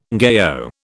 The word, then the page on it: ngaio: British English